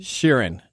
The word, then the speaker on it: Sheeran: All right, Ed Sheeran was hacked. Is that the right way to pronounce it? – Sheeran. – Is it Sheeran? Ed Sheeran.